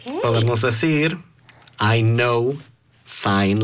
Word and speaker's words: sign: Se deletrea S-I-G-N. Pero se pronuncia sign. – Sign. – Sí, está un poco raro que la G sea muda en esa palabra.